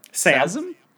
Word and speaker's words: Sa5m: How do you pronounce that? – Sasm. – Why is the five in there? – The five is silent.